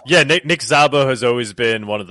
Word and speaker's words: Szabo: Nick, I think Szabo, I'm not sure how to say his last name. – Szabo, okay.